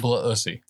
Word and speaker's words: Blissey: Pronounced Blissey.